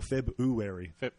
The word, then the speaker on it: February: Is it February or February? – February. It's kind of a half silent R. – Yeah. – February. – So I say a W. February.